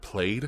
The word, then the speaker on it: Plaid: Yeah, it says like it's like 89,000 for long range, and then for was it played? I think it said PLAID played. Yeah, that's how you say it.